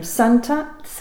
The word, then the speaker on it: Santat: Dan, I don't know how you say his name, Santat, yes. – S-A-N-T-A-T, yes.